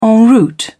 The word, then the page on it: en route: British English